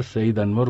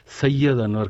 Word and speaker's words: سعید انور: اور کابل سے رپورٹیں بھیجنے والے نامہ نگار سعید انور کو ہمارے یہاں سے کئی بار سید انور کہہ کے پکارا گیا۔